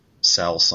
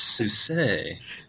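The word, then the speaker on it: Sousé: like, it even starts, like, there's two ladies just reading his mailbox, and it says Egbert Souse on it, and they're like, no, there's an accent on the E, it's Souse.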